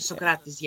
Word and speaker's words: Socrates: spot on. Socrates, yeah, well done. – I just was thinking that because they're saying Socrates, and I was like, but I've always thought it was Socrates,